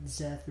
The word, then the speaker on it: Zeth: And follow me at Zeth Aureliaraza. Aureliaraza is spelled with an R. – His name is spelled Z-E-T-H.